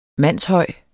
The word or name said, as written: mandshøj